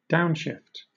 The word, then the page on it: downshift: British English